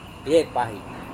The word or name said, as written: yepahi